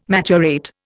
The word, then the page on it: maturate: Indian